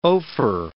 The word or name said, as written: Ophir